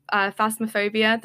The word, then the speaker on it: Phasmophobia: I mean, like I did branch out and play Phasmophobia. Did I pronounce it right this time? – Yeah, you did.